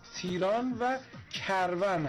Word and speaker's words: تیران و کرون: من اونجا در مورد روستای تندران گفتم که از استان اصفهان هست، بخش تیران و کرون. آقای امیر دهقانی از اصفهان پیام دادن، گفتن که خواستم بگم تلفظ اون شهرستان در اصفهان رو اشتباه خوندیم، تلفظ درست تیران و کرون هست.